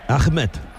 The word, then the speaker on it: Ahmed: Yo todavía estoy pensando en cómo ha pronunciado Ahmed. – Ahmed. – Me ha parecido, claro, maravilloso. – Sí. Como se debe pronunciar.